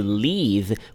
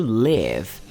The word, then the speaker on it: leave: Não vai confundir esse to leave com o to live. Live, L-I-V-E. Esse a gente já aprendeu lá no Practice Your English Everyday. Esse daqui, o to leave, se escreve L-E-A-V-E. e a v e leave, só que a gente fala com som de i natural, to leave, que é o verbo partir, sair, deixar, ok?